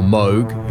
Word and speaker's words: Moog: There is also an old-school Moog, or Moog, if you want to use the proper pronunciation, that enters in verse 2.